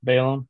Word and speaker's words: Balaam: Bela, what, how you say his name? – Balaam of Peor.